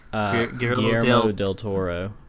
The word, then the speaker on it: Guillermo del Toro: Guillermo del Toro.